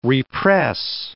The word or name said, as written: re-press